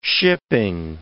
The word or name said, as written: shipping